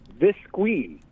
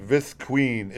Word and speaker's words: Visqueen: That's V-I-S-Q-U-E-N. Visqueen. – E-E-N. Visqueen. It's queen with V-I-S in front of it.